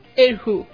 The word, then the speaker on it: erhu: En esta pieza musical, con el acompañamiento del piano, destaca el sonido del erhu. ¿Cómo pronuncio, profe? – Erhu. – Ya. Erhu.